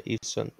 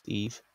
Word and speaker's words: Yves: Yves, well, it's Yves Saint Laurent, the designer, Yves Saint Laurent, or Saint Laurent, I don't know. I don't know how to say it, but I'm sure the first bit's Eve, like Yves.